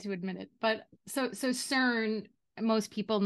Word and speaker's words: CERN: So then the next theory is CERN, that CERN is responsible. And I remember the first time we talked about this, I pronounced it Kern, and you were like, Kern?